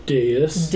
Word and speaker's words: dais: Dais. How do you say that word? – Dais. – Dais. – Mm-hmm. It's a dais, it's a dais.